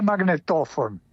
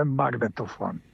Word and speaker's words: magnetofon: Kajkavski, urbani kajkavski, sa germanizmima koristi ekspiratorni, onaj izdisajni akcent na kraju riječi. Bila ona dvosložna, trosložna, nije magnetofon, nego je magnetofon.